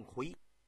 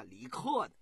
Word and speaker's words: 逵: 曾经任这个魏文侯的相，主持过变法，也有把这个李逵这个逵您可听明白了，可不是那个梁山水泊梁山里头的那个李逵，他是左边一个竖心，右边一个里外的李字，这字念逵。也有把这个李逵念作李克的，哪个克呀？就是克己复礼攻克困难的克。可是有的古书将这个李克写成什么呢？